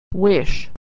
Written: wish

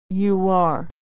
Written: you are

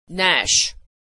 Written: gnash

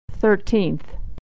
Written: thirteenth

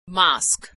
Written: mosque

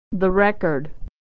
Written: the record

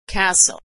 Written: castle

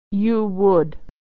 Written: you would

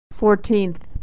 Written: fourteenth

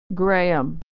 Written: graham

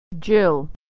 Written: jill

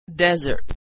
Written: desert

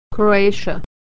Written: Croatia

